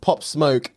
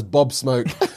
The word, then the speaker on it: Pop Smoke: Mispronounced Pop Smoke as Bob Smoke.